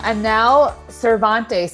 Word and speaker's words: Anel Cervantes: Welcome, Anel Cervantes.